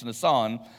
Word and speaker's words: Nisan: You know, if you're familiar with that word, it's spelled like Nisan, but it's Nisan.